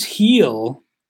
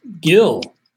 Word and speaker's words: Gil: Now, on the pitching side, a guy making some waves at the big league level already, Luis Heel. I was told it was pronounced Heel. Obviously, when you see it how it's spelled, do you think at a glance it's just Gil, G-I-L?